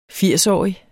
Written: firsårig

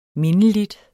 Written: mindeligt

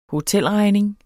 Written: hotelregning

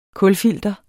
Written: kulfilter

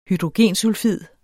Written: hydrogensulfid

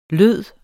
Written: lyd

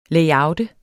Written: layout

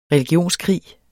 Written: religionskrig

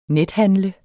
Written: nethandle

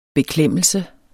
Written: beklemmelse

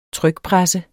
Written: trykpresse

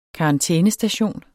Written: karantænestation

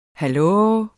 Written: hallo

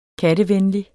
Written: kattevenlig

